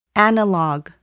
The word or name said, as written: analog